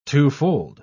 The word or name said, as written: twofold